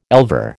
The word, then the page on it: elver: American English